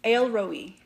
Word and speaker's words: El Roi: El Roi is how you say that.